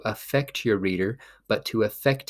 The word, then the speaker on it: affect: And in this case, it is pronounced as affect.